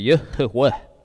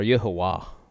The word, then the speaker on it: Yahweh: Yeah, more the pronunciation would be Yahweh or Yahuwah.